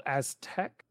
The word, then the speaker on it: Aztech: Let me pull it up on the screen here for everybody, including you, called Aztec. – Mm. – But it's spelled A-Z-T-E-C-H.